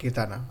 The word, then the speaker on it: Keerthana: Welcome to the pod, Keerthana. I hope I pronounced your name right. – Yes, yes.